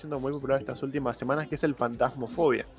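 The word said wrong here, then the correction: Phasmophobia: Sí, fasmofobia.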